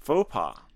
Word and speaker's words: faux pas: Harold 解释说，在很多西方国家，公开谈钱是 faux pas. Faux pas is spelled F-A-U-X. Faux and pas, P-A-S.